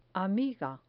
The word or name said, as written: amiga